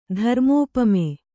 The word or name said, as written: ಧರ್ಮೋಪಮೆ